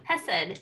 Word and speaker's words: Hesed: Some hesed, as my people say, loving kindness. – What is it? Hesed. What's the word? – Hesed. It's a Hebrew word for loving kindness. – H-E-S-S-I-D? – H-E-S-E-D.